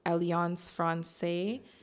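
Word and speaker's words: Alliance Française: But it was, you know, also in partnership with AF, which is Alliance Francaise.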